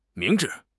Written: 明智

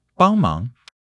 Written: bang1 mang2

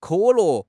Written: ke3 le4

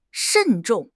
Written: 慎重

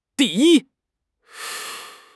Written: di4 yi1

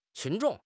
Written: 群众

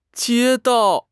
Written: jie1 dao4

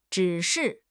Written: 指示

